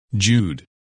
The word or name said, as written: Jude